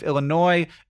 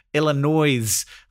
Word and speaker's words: Illinois: That's great, but she keeps calling my home state of Illinois, Illinois. Like she pronounces the S at the end.